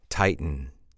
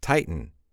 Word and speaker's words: tighten: Tighten, tighten. Which, by the way, sounds exactly like Titan. Let me explain. They are pronounced exactly the same.